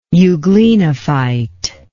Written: euglenophyte

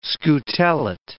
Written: scutellate

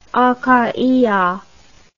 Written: akhaia

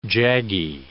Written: jaggy

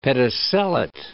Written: pedicellate

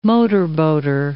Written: motorboater

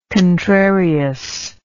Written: contrarious

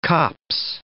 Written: copse

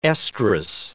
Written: estrous

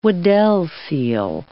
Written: Weddell seal

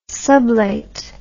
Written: sublate

